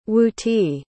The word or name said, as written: wuti